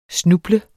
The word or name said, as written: snuble